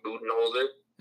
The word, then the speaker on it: Budenholzer: I would think the next one would be probably Budenholzer. If I'm even saying that right, Coach Bud. – Mike Budenholzer.